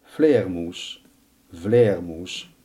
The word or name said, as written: vleermuis